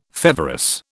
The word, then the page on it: feverous: British English